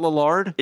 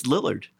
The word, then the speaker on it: Lillard: It's not Lillard? – It's Lillard. – Oh, well, I've been saying it wrong my whole life.